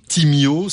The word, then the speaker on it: Timyo: Timio, ça s'écrit donc T-I-M-Y-O, qui veut dire Time is my own.